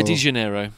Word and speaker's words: Janeiro: Yeah, De Janeiro. The J is a hard J. Think Jose Mourinho.